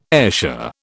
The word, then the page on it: Ayrshire: British English